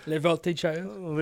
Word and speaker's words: Voltigeurs: Yeah, I think I pronounced that correctly Voltigeurs. For my French-Canadian... – Les Voltigeurs.